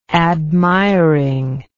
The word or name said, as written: admiring